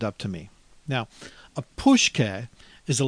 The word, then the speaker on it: pushke: Pushke, and it's spelled P as in Paul, U-S-H-K-E. Pushke.